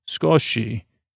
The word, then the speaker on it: sukoshi: What about skosh, a small amount, comes from the Japanese word sukoshi. S-U-K-O-S-H-I, Sokoshi, S-U-K-O-S-H-I, which is pronounced, if I would have read a little bit, Skoshi, which means a tiny bit.